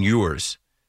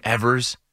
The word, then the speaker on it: Ewers: It's Quinn Ewers. What I call him? Quinn Evers? I think because it's spelled E-W-E-R. Quinn Ewers.